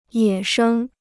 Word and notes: 野生: yě shēng

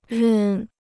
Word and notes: 任: rèn

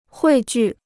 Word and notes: 汇聚: huì jù

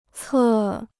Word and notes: 侧: cè

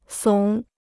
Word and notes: 耸: sǒng

